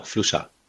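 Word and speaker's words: Fluxà: Und ist das richtig ausgesprochen, Fluxa? – Genau, Fluxa. Das ist hier Mallorquin.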